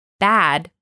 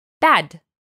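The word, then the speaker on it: bad (بد): But make sure to pronounce it the Persian way. Not bad, but bad.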